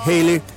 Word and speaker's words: Haley: Como su nombre, Hele, se pronunciaba parecido al famoso cometa, entonces lo dieron vuelta y quedó Bill Haley and his Comets.